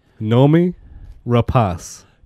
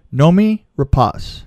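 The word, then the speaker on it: Noomi Rapace: Well, she pronounces her name. I looked it up, and okay. She herself said, Nomi Rapace. – Nomi Rapace.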